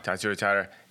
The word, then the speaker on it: Tatsuro Taira: Tatsuro Taira.